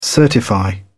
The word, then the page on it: certify: British English